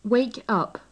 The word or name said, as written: wake up